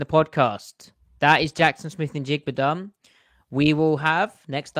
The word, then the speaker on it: Smith-Njigba: uh like in my mind when I think Jaxon Smith-Njigba over route or crosser route, like that's kind of the example, probably.